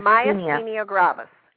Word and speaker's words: myasthenia gravis: I was listening to you guys talk about, I don't want to mispronounce it, but myasthenia. – Myasthenia gravis, yes.